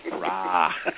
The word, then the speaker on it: RAA: It's been nearly 6 years I've been asking people, do they remember how to spell A-U-D-A, because that's what we're called, not outer. – On the other hand, I've never heard anyone try to pronounce R-A-A. Rah.